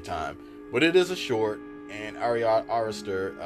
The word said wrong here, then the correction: Ari Aster: uh, am I pronouncing his name correctly? I, I think Ari, I think Ari, it was Aster. Aster. Ari. – Ari Aster. Aster. yeah.